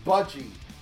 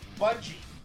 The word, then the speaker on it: Budgie: I always pronounce, is it Bungie or Buggy? Or it's Budgie. – Budgie.